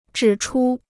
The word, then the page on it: 指出: zhǐ chū